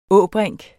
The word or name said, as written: ås